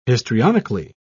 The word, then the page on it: histrionically: British English